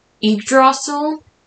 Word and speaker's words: Yggdrasil: And, yeah, I think that is so, so fascinating, and that is a very good point that Yggdrasil, that's how you pronounce it? Yep, the world tree.